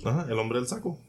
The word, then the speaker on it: El Hombre del Saco: In Spain, there is El Hombre del Saco. Am I saying that right? – El Hombre del Saco.